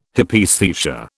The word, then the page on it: Hypaesthesia: British English